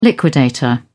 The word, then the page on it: liquidator: British English